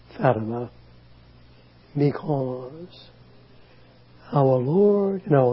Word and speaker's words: Fatima: As I told the people, Fatima, that's the pronunciation, the favorite daughter of Muhammad.